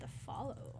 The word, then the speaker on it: The Folo: I think they actually pronounced it The Follow.